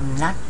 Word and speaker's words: knock: And uh, for the grammar one, I want to talk about knock down and knock over. Knock, remember the pronounce K 不发音嘛，哈，就 knock down, knock over.